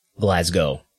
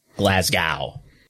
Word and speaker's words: Glasgow: Bonus points if you want to pronounce it Glasgow, as I just did, and not Glasgow, like a damn dumb yank.